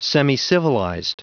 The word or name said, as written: semicivilized